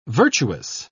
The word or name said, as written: virtuous